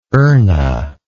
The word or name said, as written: erna